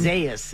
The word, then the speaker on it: Isaias: We don't know how to say it. It's the eye storm. – Isaias is what the pronouncer says. – It seems like it's Isaias, but I remember it being said a different way.